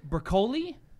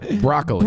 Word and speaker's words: Broccoli: So James Bond, to your note from earlier, the James Bond producer, Barbara, and I don't know how to pronounce it, Broccoli? – Broccoli. – Broccoli? – Broccoli. – It's only broccoli.